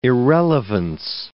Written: irrelevance

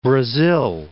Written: Brazil

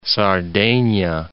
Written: Sardegna

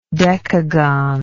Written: decagonally

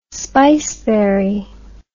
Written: spiceberry

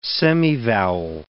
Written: semivowel

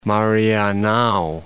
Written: Marianao

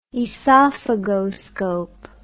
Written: oesophagoscope

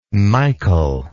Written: michal

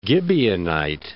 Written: Gibeonite

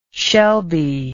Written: Shelby

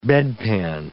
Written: bedpan

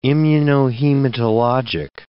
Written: immunohematologic

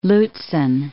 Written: Lutzen

